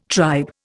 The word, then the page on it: tribe: Indian